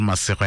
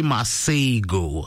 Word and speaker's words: Masego: You don't pronounce it Masego. Hela garitore mustwane wato Masego. No, you say Masego.